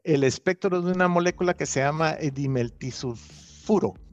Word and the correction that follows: dimetilsulfuro: a ver si lo digo bien. – DMS en, inglés, la, dimetilsulfuro.